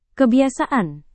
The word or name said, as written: kebiasaan